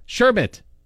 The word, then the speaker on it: sherbet: S-H-E-R-B-E-T. How do you say it? – Sherbet. – No. – What? – Sherbet. There's no R.